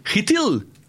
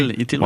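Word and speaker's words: Chytil: Avec, là, par contre, là, sa ligne va bien ces temps-ci, avec Capo Kako et Philippe Hrithil. – Bien prononcé. – Hrithil, wow. C'est vraiment ça.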